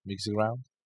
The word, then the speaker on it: Kiss the Ground: Kiss the Ground.